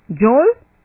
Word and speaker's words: Joel: Joel? Yeah. – How do you spell it? – J-O-E-L.